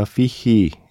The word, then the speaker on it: 피지: 와니와 준하에서 I Wish You Love 리사 오노의 노래 그리고 미술관 옆 동물원에서 Let There Be Love 로라 피지 이게 네덜란드어 발음을 검색하면 로라 피히 이렇게 나오던데 우리에게 익숙한 발음으로 소개했습니다. 로라 피지의 노래였고요.